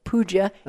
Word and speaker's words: puja: Puja. Okay. It's P-U-G-A,